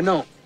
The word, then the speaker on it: gnome: First of all, if you were a gnome, you would know that the G in gnome is silent. It's gnome, okay? – No, it's gnot.